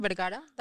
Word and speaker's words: Vergara: You did fine, Vergara, Daniela Vergara, that's good.